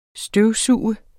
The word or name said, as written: støvsuger